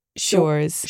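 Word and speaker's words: Schuurs: S-C-H... – the spelling of my name. – S-C-H-U-U. Mm-hmm. Mm-hmm. But you actually pronounce it... – Shores. – Shores. – Yes, yeah. – As in... She sells seashells by the seashore.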